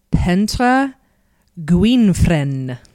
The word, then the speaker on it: Pentre Gwynfryn: and I actually looked up the pronunciation. It's Pentre Gwynfryn. Yeah, doesn't that sound great? – Sounds beautiful. – It does sound beautiful. It's not spelled at all like that.